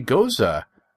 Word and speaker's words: Gose: This beer is, and again, I learned that the pronunciation for the G-O-S-E beer, we were calling it the Goza, is actually Goza.